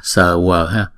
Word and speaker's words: Swaha: Maha Samayam, Samayam Swaha, chữ này chúng ta đọc là sờ quờ ha.